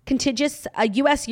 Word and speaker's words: contiguous: I can never pronounce that word. Contiguous? – Contiguous. Is there an extra N? – No, there's no contiguous. Contiguous US, Europe, Canada, and Australia, except for the personalized products.